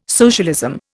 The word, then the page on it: socialism: Indian